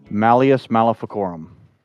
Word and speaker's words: Malleus Maleficarum: the Malleus Maleficorum? Did I say it right? – You got really, really close. – So we'll say it. – Malleus Maleficorum. – Ah, Malleus Maleficorum, which is Latin for the Hammer of Witches.